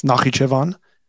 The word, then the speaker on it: Nakhichevan: Now, this little segment here, it's actually pronounced Nakhichevan.